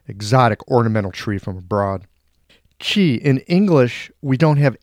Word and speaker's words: qi: Today, I'm answering the question, what is this qi stuff? Qi is not pronounceable in English. It's a whistled aspiration that's not a sound that we have in our language.